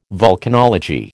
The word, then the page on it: volcanology: American English